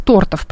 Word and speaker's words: тортов: А торт был из тортов, правильно, да, сказать ударение, было только сказка.